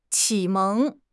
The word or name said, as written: 启蒙